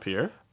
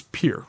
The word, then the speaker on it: Pierre: It's not Pierre? – It's Pierre. – Oh. – From what I hear, you pronounce it Pierre.